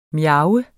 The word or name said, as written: miaves